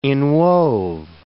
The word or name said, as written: inwove